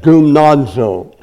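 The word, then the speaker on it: gumnazo (γυμνάζω): This word training is a word gymnazo in the Greek. The Greek word You can say it gumnazo, gumnazo. I suppose you can spell it G-U-M-A-Z-O, gumnazo.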